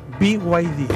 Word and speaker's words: BYD: BYD. – Es B larga y griega D. En chino se dice BYD. – ¿Hablas chino? – Es como el BID, pero con Y.